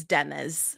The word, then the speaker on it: Deniz: I'm telling you, it's spelled D-E-N-I-Z. – It is. – Is it not Deniz, but Deniz? – On the pronunciation, it says D-U-H, which is duh, and N-E-E-Z, which is knees.